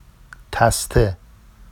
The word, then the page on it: taste: Vafsi